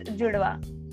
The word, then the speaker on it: Judwaa: It's pronounced Judhwa.